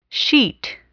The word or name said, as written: sheet